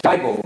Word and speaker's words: Dybel: He pronounces his name Dybel, which rhymes with Bible.